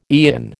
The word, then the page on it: eon: American English